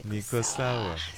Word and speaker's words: Niko sawa: How do you say it in Swahili again? – Ndiku sawa. – Yes, Niko sawa.